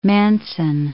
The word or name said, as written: Manson